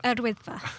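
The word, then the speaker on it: Yr Wyddfa: Recent hot weather means there's been a bumper summer crowds at Yawadifa. Is that how you pronounce it? – Erwidfa. – Thank you. Thank you to our Welsh experts.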